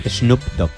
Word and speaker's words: Snoop Dogg: Snoop Dogg. ¿Se pronuncia así? – No sé. – Snoop Dogg. – Sí.